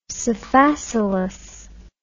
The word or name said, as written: sphacelus